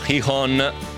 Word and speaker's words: Gijón: Se la città di cui parlavi è quella spagnola di Gijón, si pronuncia più o meno Gijón con le H aspirate, grazie a Paolo D'Asti.